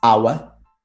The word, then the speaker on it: hour: There are only four words in which the H is silent. And obviously, the, the words that are either compound or, or that come from these words. And they are our, honor, honest, air.